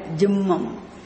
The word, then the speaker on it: jihmam: Jihmam, actually when you read it, ich is silent, jimmam. For you to write, I am telling this, okay? Jihmam. Jihmam. Jihmam. Ha. H. J-I-H.